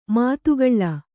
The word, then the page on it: ಮದಕಲ: Kannada